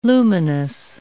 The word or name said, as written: luminous